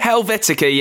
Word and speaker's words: Helvetica: Helvetica, yeah.